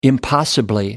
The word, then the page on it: impossibly: American English